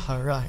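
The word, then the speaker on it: حرہ: میں نے last time غلط تلفظ کیا تھا, ضمہ کے ساتھ حرا میں نے کہا تھا, نہیں, واقعہ حرا ہے, ٹھیک ہے.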